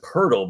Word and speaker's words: Poeltl: And it was funny because I told my son and my wife, I'm like, the greatest thing about this is his name is pronounced Pertle, but there's not even an R in it.